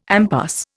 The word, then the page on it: impasse: Indian